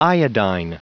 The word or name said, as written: iodine